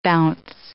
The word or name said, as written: bounce